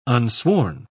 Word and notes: unsworn: British English

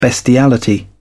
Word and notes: bestiality: British English

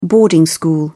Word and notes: boarding school: British English

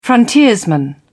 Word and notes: frontiersman: British English

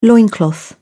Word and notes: loincloth: British English

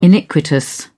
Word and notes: iniquitous: British English

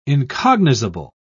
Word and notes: incognizable: British English

